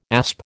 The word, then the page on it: asp: American English